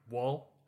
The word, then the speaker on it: Woll: Deborah Ann Woll, I believe is how you say her last name.